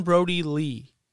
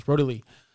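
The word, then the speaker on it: Brodie Lee: And he chases down Brody Lee. Did I say his name right? – Yep. – Yeah, Brody. Okay. I don't know why I thought it was like Brody Hubbard. Well, no, it's Brody Lee.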